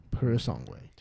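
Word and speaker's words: Purosangue: Purisangue.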